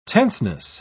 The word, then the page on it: tenseness: British English